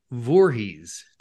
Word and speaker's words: Voorheis: Today's guest is Deb Voorheis.